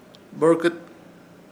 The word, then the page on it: Borkɨt: Ishir Chamacoco